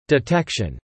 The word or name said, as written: detection